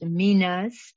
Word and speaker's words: Minas: It is pronounced Minas. I'm not used to this translation. It's Greek and it is Mina or Minas. She is correct.